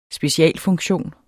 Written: specialfunktion

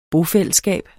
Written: bofællesskab

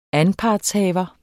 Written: anpartshaver

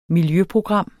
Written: miljøprogram

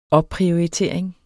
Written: opprioritering

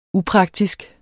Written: upraktisk

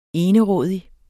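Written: enerådig

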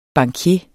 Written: bankier

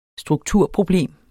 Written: strukturproblem

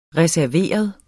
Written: reserveret

